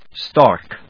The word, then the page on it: stark: stάɚk ｜ stάːk